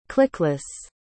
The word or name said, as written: clickless